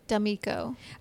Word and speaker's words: D'Amico: My full name is Tamela D'Amico.